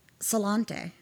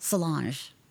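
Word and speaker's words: sláinte: a drink and cheers was salante. – Mm-hmm. – I thought that's what it was. It's not. It's spelled salante, but it's pronounced Solange.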